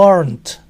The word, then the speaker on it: aren't: Cuando este aren't es la palabra final, la T se pronuncia. Si le sigue otra palabra a aren't, la T puede dejar de pronunciarse.